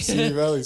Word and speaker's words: Simi Valley: Is it semi or semi? – Simi Valley. – Is that one eye silent?